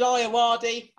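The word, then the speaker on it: Ayoade: Richard Iowardi. And I did say that right because I checked.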